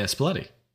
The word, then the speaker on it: Spalletti: Luciano Spalletti. – Oh, yeah, Spalletti. – Did I say it right? – Yeah, I think so. That's how you pronounce it.